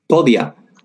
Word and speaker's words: Podia: Podia.